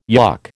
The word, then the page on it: yuck: American English